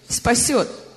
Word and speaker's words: Спасет: Ну сказано, что молитва веры спасет. – Спасу. – Спасет. – Did I say that correctly? – Сказал правильно? – Shall save. – Спасет. – Спасу. – Спасет. Like a T at the end.